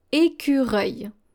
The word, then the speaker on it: écureuil: Here, the final L is going to be silent as well. So this is how you pronounce it. Écureuil. Un écureuil. Écureuil.